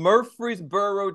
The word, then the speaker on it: Murfreesboro: And so you're originally from Murfreesboro, Tennessee. Is that correct? – That's correct. You pronounced it correct as well.